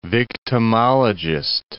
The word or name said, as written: victimologist